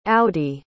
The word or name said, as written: audi